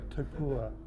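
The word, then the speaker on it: Taupō: There's certainly an appetite there from them, and then you look at what venues are available to you, a bit Hamptons or that's not the correct pronunciation. Taupua. – Thanks. Taupua. Yes.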